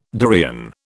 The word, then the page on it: durian: British English